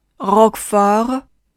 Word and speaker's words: roquefort: 最后一个词 roquefort。在发这个单词时，你需要注意两个 o 都是大口的，所以需要把嘴稍微张大一些。 o rock for rock for 开头的小舌音需要尽力的颤动或者摩擦。 rock for 最后，请你再跟我读一遍发音难点。 Monsieur Monsieur choisi choisi oignon oignon roquefort roquefort 好的，接下来就是你练习的时间了。